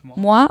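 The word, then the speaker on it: Toro y Moi: Imoy, like in French. – Moi? Okay, yeah. I thought I was saying it wrong last time.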